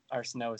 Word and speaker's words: Arsenault: It's at PArsenault19, and the last name Arsenault is spelled ArsenAult.